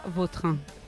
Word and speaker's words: Vautrin: Más puede repetir más... la pronunciación. – Vautrin. Sí. Elegante. – La original francesa, Vautrin. – Ahí pega un Vautrin. – A mí me gusta Vautrin.